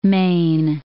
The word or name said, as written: Spanish Main